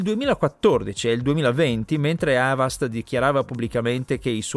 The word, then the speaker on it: Avast: Avast o Avast, secondo la pronuncia inglese corretta, che però in italiano non usa praticamente nessuno, è un nome molto noto nel campo della sicurezza informatica, in particolare per i suoi antivirus.